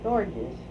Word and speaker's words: Gorges: Are you calling her Georges or Gorgeous? – I thought it was George. Is it Gorgeous? G-E-O? – No, it's spelled here Gorgeous. – And I mispronounced it. I didn't look at it that closely. You're right, G-O-R-G-E-S. – Uh-huh. – Gorgeous. – Gorgeous. – Gorgeous, right.